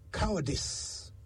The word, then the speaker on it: cowardice: It's cowardice. That is how you say it right.